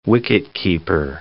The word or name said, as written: wicketkeeper